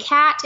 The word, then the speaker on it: Kat: And cat is with a K, K-A-T. – K-A-T. And Benefab's is B-E-N-E-F-A-B.